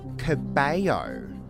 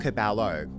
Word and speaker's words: Cabello: The correct pronunciation is Camilla Cabello. – Oh. – It's not Caballo. – Cabello. – Yeah. There's no L. You don't pronounce the LL.